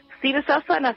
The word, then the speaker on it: Sirsasana: Sirsasana se escribe con en doble S. S-I-R-S-A-S-A-N-A.